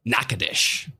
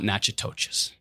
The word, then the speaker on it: Natchitoches: We're talking Bossier City, Monroe, Alexandria, Ruston, and Natchitoches. Not Natchitoches.